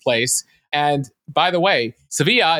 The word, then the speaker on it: Sevilla: I think the Spanish call it Sevilla. Sevilla, Jill. Do I have that better? Is that better than pistachio? – It is. It is. They do pronounce it Sevilla.